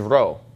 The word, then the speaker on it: rho: This is rho, so you don't want to get those two mixed up. Whenever you see this when you're reading Greek, you need to get used to pronouncing this as an R sound and not as a P sound, and just keep in mind what the P looks like.